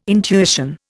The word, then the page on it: intuition: Indian